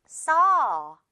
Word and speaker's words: saw: 자, 그런데 이건 떡 발음이다 보니까 어떻게 발음해야겠어요? saw 이렇게 발음이 됩니다. 마치 약을 먹고 약이 써서 써 이렇게 말하거나 또는 싸에 가까운 느낌? 싸! 거의 이런 수준으로 싸 또는 싸처럼 들릴 거예요. 쏘는 아니에요. 그리고 싸우 당연히 아니고요.